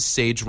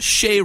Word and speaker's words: Sage: S-A-G-E Romberg. We highlighted her. Make sure you say her name is Sage Romberg, not Shay Romberg, as Brian Tesh continually called her through the webcast.